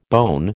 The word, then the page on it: bone: American English